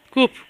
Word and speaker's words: CUP: Y ahora estás, creo que es la sexta o la séptima, por las listas de Barcelona de la CAP, ¿verdad? – La séptima. Sí. – La séptima. – No digas CAP porque es como si CUP. Lo pronunciaras en inglés. – CUP, perdón.